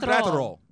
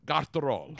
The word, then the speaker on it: Graterol: Now, according to Hitman 17, Brusdar Grat—I got it. See, I'm good with Spanish pronunciation. – Brusdar Graterol. – Graterol.